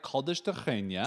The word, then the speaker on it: Caldas da Rainha: Well, we went to nearby Caldas da Rainha. – Da Rainha. – Which that is how you pronounce it. We're not doing a voice or anything. Just FYI, the R's in Portuguese are the sound.